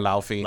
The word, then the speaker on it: Laufey: However, it turns out that Faye was actually a giant called Luffy. I think I'm pronouncing that correctly. – Luffy or Laufey, depending on who you ask.